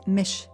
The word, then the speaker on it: Mich: If you're not sure how to say my name, which is spelt M-I-C-H, short for Michelle, it's Mish with an ish, I-S-H.